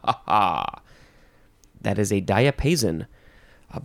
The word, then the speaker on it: diapason: Diapason. Diapason or diapason. That's how you say it. D-I-A. P-A-S-O-N, noun from circa 1501.